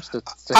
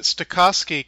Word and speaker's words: Stokowski: How'd you pronounce his name? – Stokowski? I pronounced it Stokowski because I watched a little documentary on Fantasia and I think that's how they pronounced it.